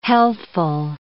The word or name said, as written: healthful